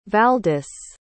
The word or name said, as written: valdis